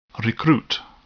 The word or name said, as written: recruit